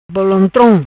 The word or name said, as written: Bolontrom